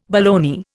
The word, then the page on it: baloney: Indian